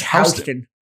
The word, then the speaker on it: Houston: Because back east, Houston, like there's a Houston street in New York City. And they don't pronounce it like that. It's Houston. It's Houston is how they pronounce it back then.